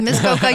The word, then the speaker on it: Muskoka: I'm actually out in Bridgehampton, which is sort of like the Muskoka of Canada, or however you pronounce it. – Muskoka, you got it.